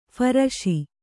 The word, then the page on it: ಫರಸಿ: pharasi